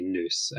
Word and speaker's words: nous: because I read not just the same Wikipedia article as you, but, like, I mean, it comes from the word nous, or I guess Americans say nous, which is intellect or intelligence.